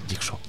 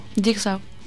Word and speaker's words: Jigsaw: ¿Cómo se dice? Por favor. – Jigsaw. – Jigsaw. – Jigsaw. – Jigsaw. Así, no, muy bien, intentaré no repetir.